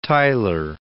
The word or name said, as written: Tyler